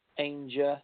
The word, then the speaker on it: Ainger: It's Clayton John Ainger, A-I-N-G-E-R.